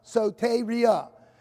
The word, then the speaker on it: soteria: Soteria. It's spelled S-O-T-E-R-I-A. Soteria. And it's pronounced soteria.